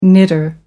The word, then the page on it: knitter: American English